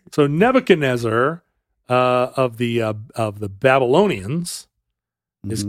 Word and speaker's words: Nebuchadnezzar: Now, how do you pronounce that? – I say Nebuchadnezzar. – Nebuchadnezzar is how they say it.